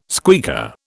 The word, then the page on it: squeaker: British English